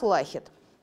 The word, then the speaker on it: Лахет: Итак, у нас Лахет. Можете называть его Лахетом, как хотите. Тут можно сказать ударение куда угодно, это ваше дело.